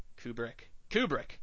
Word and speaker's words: Kubrick: Kubrick.